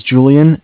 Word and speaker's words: Julian: All right, so my name is Julian. It's J-U-L-I-A-N. I'm calling with Bank of Cardiff. Cardiff is spelled C-A-R-D as in David, I-F-F, like Frank.